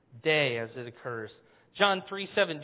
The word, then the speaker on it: Dei: The word is, and I spelled it out for you there in English, D-E-I. You'd pronounce it day.